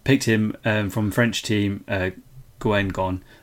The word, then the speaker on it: Guingamp: I believe it's pronounced Guingamp. – Guingamp.